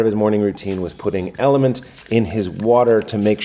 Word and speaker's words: LMNT: So he kept saying, I put element in my water, but uh, I couldn't find it when I searched it. It's because it's L M N T. It's spelled L, the letter L M N T.